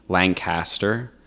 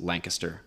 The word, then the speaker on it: Lancaster: Lancaster is the most common. Because I don't know if this is true because I've never been there, but I've heard that there is a Lancaster, California, but it is, for the record, officially, it is Lancaster, Pennsylvania. – Two syllables real quick and then one.